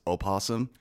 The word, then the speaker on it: opossum: The actual word is opossum. – But you don't pronounce the O.